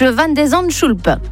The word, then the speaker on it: van den Zandschulp: Le Français affronte ce soir à New York le 70e mondial, le Néerlandais Botic van den Zandschulp. – Merci et bravo pour la bonne prononciation.